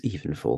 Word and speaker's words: Evenfall: It's Evenfall.